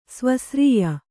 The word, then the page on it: ಸ್ವಸ್ರೀಯ: svasrīya